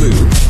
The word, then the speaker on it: Louisville: So it's Louisville. Like a vault. Louisville. – Not even a vault. Like full, but vault.